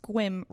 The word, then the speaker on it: Sequim: So it says Sequim, Washington, but he says it's pronounced Squim, rhymes with whim.